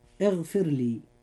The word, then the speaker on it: اغفر لي: كلمة اغفر لي، الراء في هذه الكلمة جاءت مرققة لأنها ساكنة وقبلها كسر أصلي وليس بعدها حرف استعلاء، فتنطق مرققة هكذا. – اغفر لي.